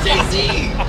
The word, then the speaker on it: Jay-Z: It's two syllables, man. It's Jay-Z.